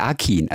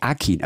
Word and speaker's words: Akın: Ich fand es schon so schön, wie du ihn jetzt ausgesprochen hast, weil wir Bio-Deutschen sagen nur Fatih Akın. Also Fatih, wenn wir richtig gut sind. Aber dann ist es natürlich Akın. Aber du Akın und das Fatih K. Akın.